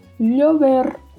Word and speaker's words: llover: E a letra V, que tem leve som de B. O E é bem fechado e o R com aquele vibrato que já comentamos várias vezes. Então, umas possíveis pronúncias seriam llover, jover jover chover.